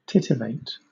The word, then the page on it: titivate: British English